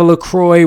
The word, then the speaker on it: LaCroix: I sometimes have a LaCroix with it. It's also pronounced like this. You don't say the X.